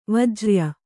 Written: ವಜ್ರ್ಯ

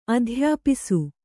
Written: ಅಧ್ಯಾಪಿಸು

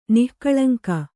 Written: ನಿಃಕಳಂಕ

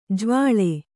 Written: ಜಾವಳೆ

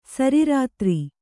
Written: ಸರಿರಾತ್ರಿ

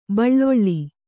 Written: ಬೆಳ್ಳುಳ್ಳಿ

